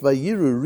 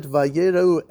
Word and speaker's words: vayeirau: It says Rabbi Avahu, it's not to be pronounced vayiru. Read it vayeirau, and the nation became visible because Aaron had died.